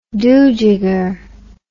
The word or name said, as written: dojigger